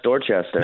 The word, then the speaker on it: Dorchester: Dorchester? – Yeah, that's Dorchester.